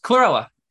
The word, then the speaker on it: chlorella: I think some people would do cholera, the infectious disease. I'm saying it wrong. The little green, Chlorella.